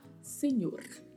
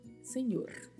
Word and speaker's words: señor: Atenção para a pronúncia dessa letra N, a letra N com o tio em cima, que tem o som de NH, e o vibrato do R ao final. Senhor, Senhor.